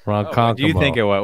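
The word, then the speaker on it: Ronkonkoma: Sometimes you got to switch at Ronkonkomo, that's usually where the switch happens for Greenport. – That's right. – You got to switch. – I didn't know how to pronounce it. – Ronkonkomo.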